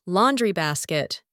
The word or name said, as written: laundry basket